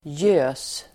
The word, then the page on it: gös: [jö:s]